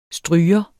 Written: stryger

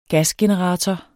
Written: gasgenerator

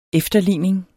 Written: efterligning

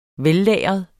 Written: vellagret

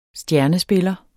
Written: stjernespiller

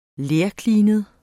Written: lerklinet

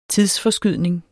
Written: TIDSFORSKYDNING

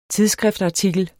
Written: tidsskriftartikel

